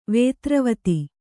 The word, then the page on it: ವಿದ್ರಾವಿತ: vidrāvita